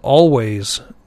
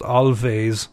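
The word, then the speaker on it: Alvvays: They're called Always. The W is actually spelled as two V's, so it's not pronounced Alves, although that is how my Polish grandmother would pronounce it anyway, I expect.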